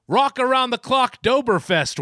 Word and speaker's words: Rockaroundtheclockdoughberfest: It's Rock Around the Clocktoberfest, Wags. – You said it wrong. – What? How did I say it wrong? – You said Tober. It's Dober.